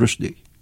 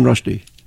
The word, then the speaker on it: Rushdie: How does he pronounce his name? How do you pronounce your name? – Salman Rushdie. – Okay. Do people mispronounce it? – Yes, Salman Rushdie. – Oh, Salman, which is probably what I said. – No, you said Rushdie. – I said Rushdie.